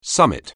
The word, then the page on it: summit: anglais